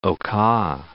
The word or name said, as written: Oka